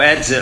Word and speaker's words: Herzl: But going back to the Mount Herzl, because I think you pronounce it Herz or Herzl. I mean, the pronunciation of it. – How do you spell it? How do you spell it? – You spell it H-E-R-Z-L. – Herzl, Mount Herzl. H-E-R-Z, Herzl, yeah.